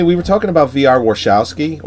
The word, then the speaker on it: Warshawski: So I looked it up on IMDb, and it's Warshawski. – Yeah. – And she even pronounces it that way in the movie.